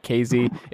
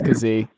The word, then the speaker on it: Kazee: Devontae Kazee, who you're a big fan of, and I'm still pronouncing it Kazee because I will not move away from that. It's not KZ, it's Kazee.